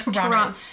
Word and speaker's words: Toronto: And by the way, it's Toronto. There is no T at the end of Toronto. I hate how Americans do that.